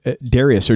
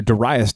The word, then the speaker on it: Darius: So chapter 6 opens up with this guy, Darius, or Darius, depending on your pronunciation there, and he is the one that comes in.